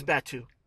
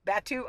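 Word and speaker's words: Batuu: Oh yeah, that is Batu. – It's Batu. – Batu.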